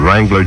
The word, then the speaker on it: Wrangler: Fellas, there's a silent W on Wrangler jeans, Wrangler sportswear, Wrangler jackets, every Wrangler in America. But you have to look for the Wrangler W because it's silent. Look in the outside of Wrangler jeans. Look for the hang tag or label on everything else. But look for that silent W, men. It means you've got a real Wrangler. – Bradley's self-service department stores and branches are the places to look for the silent W.